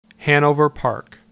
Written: Hanover Park

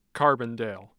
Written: carbondale

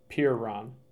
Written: Pierron

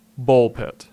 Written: Bulpitt

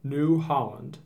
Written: New Holland

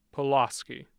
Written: Pulaski

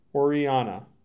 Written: Oreana

